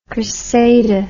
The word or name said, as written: Criseyde